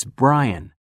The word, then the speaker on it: Bryan: K-E-R-R-Y. R-R-Y. – 4. – What's your name? – It's Brian. B-R-Y-A-N.